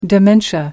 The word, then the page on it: dementia: American English